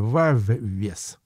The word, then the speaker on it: Wavves: Группа называется Waves. Пишется, правда, не просто вот как волны waves, а с двумя буквами V в середине вавес, как бы.